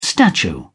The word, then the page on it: statue: British English